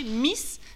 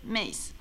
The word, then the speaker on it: Meiss: ce qui nous apprend d'ailleurs qu'on devrait prononcer Miss, mais je vais continuer à prononcer à la française Meiss.